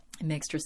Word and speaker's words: Mixtress: Mixtress, again, is M-I-X-T-R-E-S-S. Ray is spelled R-A-E.